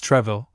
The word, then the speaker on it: Troisville: Treville, 1598 to 1672, Arnaud Jean Duperre, Comte de Troisville's, written and pronounced Treville,